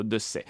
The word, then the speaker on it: Dessay: Realtà, perché la nostra amatissima Nathalie Dessay, o Dessay, chissà, su questa pronuncia ci sono molte questioni. Lei si firma addirittura 2C, che in francese si legge appunto Dessay.